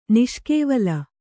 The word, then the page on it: ನಿಷ್ಕೇವಲ: niṣkēvala